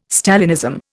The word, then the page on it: Stalinism: Indian